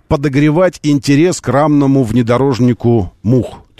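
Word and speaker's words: MU-X: А в целом это читается как мух.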